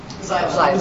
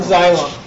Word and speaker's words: Xylem: Xylem. – Xylem.